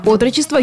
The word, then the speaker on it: отрочество: Детство, отрочество, юность, зрелость, старость. Во всех этих словах, как и в слове «отрочество», ударение падает на первый слог.